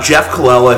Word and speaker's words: Geoff Colella: Geoff Colella, if you can spell that, you know.